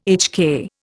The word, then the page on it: HK: Indian